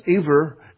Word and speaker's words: Eber: In your King James Version Bible, it's Eber, but it's pronounced Eber.